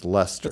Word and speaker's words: Leicester: Leicester. Oh. And I went, no, it's pronounced Lester.